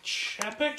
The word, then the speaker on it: Chapek: Chapek.